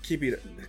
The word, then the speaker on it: Kibito: They take out Kibiru. – Kibito. You're pronouncing it wrong is making me pronounce it wrong.